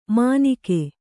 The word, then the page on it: ಮಾನಿಕೆ: Kannada